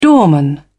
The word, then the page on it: doorman: British English